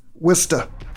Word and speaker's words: Worcester: Worcester.